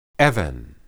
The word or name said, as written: eben